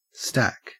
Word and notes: stack: Australian English

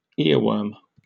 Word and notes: earworm: British English

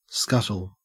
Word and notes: scuttle: Australian English

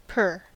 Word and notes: per: American English

